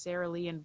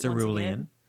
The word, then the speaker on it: cerulean: You can get the Cerulean Blue. I don't know if I said that right. – Cerulean. – Cerulean. Thank you.